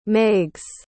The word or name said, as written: meigs